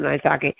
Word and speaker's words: maitake: And she gave me a gigantic maitake. Is that how you pronounce it? Maitake. – Maitake.